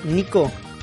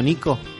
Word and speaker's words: Nyko: es un juego que, no, que todavía no le di Después de la Pokémon Direct Uli nos enteramos que la empresa Nico se viene con un montón de juguetitos que nos van a volver locos.